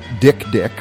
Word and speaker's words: dik-dik: and apparently there's an animal called the Dick Dick, which is spelled D-I-K-D-I-K, and it's this tiny antelope sort of creature, and it marks its territory by crying, and it looks really sad.